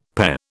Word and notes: PE: British English

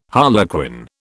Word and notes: harlequin: British English

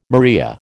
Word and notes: maria: American English

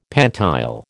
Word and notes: pantile: American English

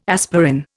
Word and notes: aspirin: Indian

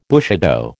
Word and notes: bushido: American English

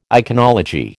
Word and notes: iconology: American English

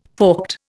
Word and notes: forked: Indian

